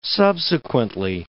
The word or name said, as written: subsequently